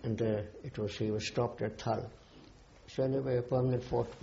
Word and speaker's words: Thal: Thal? – Thal.